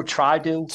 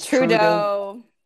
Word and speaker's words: Trudeau: or is that how you say it? Trudeau or Trideau? – Trudeau.